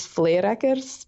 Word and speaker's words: Fleerakers: Though it's pretty close. The correct way is Fleerakers, but ooh, I've given up on that long ago.